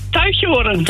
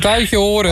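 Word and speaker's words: Tuitjenhorn: Hoe spreek je dit uit? – Ja, Tuitjehoren. – Tuitjehoren. – Tuitjehoren.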